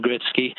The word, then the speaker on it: Gretzky: Wayne Gretzky has always been pronounced Wayne Gretzky, and I suspect Michael just wasn't familiar with how to pronounce it when he read it off off.